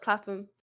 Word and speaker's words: Clapham: No, that's pronounced Clapham.